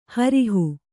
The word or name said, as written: ಹೊರಹು